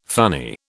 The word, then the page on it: funny: British English